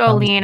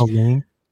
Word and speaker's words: Olin: Olin. No one can pronounce it. I'm Swedish. – I always say Olin. – It's Olin. I'm Swedish. It's Olin. – If it's Olin, it should be spelled O-L-E-E-N.